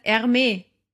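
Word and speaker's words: Hermès: Stille H, so wie bei Hansa. Sagst du nicht Hansa, sagst du Ansa. – Nein, es heißt Hermes.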